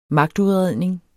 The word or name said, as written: magtudredning